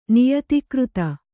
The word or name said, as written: ನಿಯತಿಕೃತ